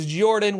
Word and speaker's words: Jordan: My name is Jordan with a silent PH in the middle, and I am joined, not always, but on a very special day, we have LB Hack'em'up back, guest co-hosting again.